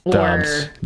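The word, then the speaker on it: Doubs: Romeo Dubs. – Dobbs. That's how they've been saying. They've been saying Dobbs all season.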